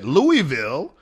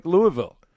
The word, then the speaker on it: Louisville: Louisville. I can say that all day, but that's not the way they say it. They say it in like one long syllable, like Louisville. That's how they say it.